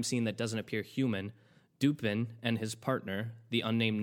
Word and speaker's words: Dupin: I think it's pronounced, and I might pronounce this wrong because it's technically French, Diepin, spelled D-U-P-I-N. It's Diepin.